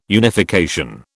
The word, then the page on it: unification: British English